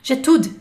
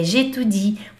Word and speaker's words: j'étudie: Por muito tempo, quando eu comecei a estudar, eu falava, eu estudo francês, j'étude. Getude, Getude. Não pode. É Getudi, porque tem esse izinho aqui antes do e.